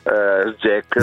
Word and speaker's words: SGECH: Come lo pronuncia lei? – SGECH. Ma si può, all'italiana.